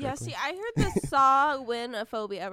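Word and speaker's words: Samhainophobia: I'll tell you how it's spelled. It's spelled SAMHAINO phobia. – That doesn't make any sense. – That doesn't make sense, but that's how it's pronounced. Sawinophobia.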